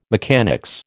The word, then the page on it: mechanics: American English